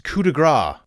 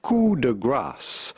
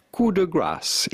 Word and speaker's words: coup de grâce: The final blow or shot given to kill a wounded person or animal, often pronounced coup de grace. That's how I used to pronounce it until I heard this. – How do you go about pronouncing this in French with the typical French pronunciation as well as in English or American? Coup de grâce. Coup de grâce. In French, coup de grâce. – Yes, you do pronounce the S at the end of coup de grâce. Or sorry, the C-E.